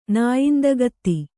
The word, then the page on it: ನಾಯಿಂದಗತ್ತಿ: nāyindagatti